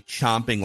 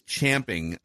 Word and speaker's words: champing: It's spelled champing, but yeah, I believe it's pronounced chomping. Most people say chomping like C-H-O-M-P as if you're chomping. Chewing on a bit. – Incorrect. – It's champing on the bit. – But anyway. – Champing Hall at the bit, technically, is how you would pronounce it.